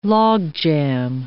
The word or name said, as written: logjam